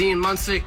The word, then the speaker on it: Munsick: Did and I pronounce it right, finally? – You sure did. Just straight from Ian's mouth. – This is Ian Munsick, a.k.a.